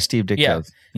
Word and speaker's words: Ditko: Yeah, Steve Ditko.